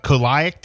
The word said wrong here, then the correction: celiac: disease shouldn't be afraid of kissing gluten eaters. – Oh, celiac.